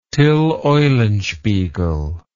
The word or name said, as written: Tyll Eulenspiegel